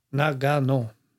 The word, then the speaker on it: Nagano: Eller man ska uttala på japanska så ska man inte ha någon betoning vet jag. Nagano.